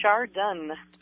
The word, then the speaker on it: Chardon: Did I pronounce Chardon, Ohio correctly? – It's Chardon.